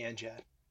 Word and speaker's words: NGAD: So F-18 and then Navy NGAD. – That's with a soft G, by the way.